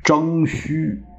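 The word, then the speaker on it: 徵舒: 这后来就嫁给了夏玉书为内子，这个大夫的妻子叫内子，生下一个男孩叫征虚。这个虚，这个虚啊，其实是舒服的舒啊，舒适的舒。那怎么念虚呢？这是个古音字，不见得字典上有，我没有去查。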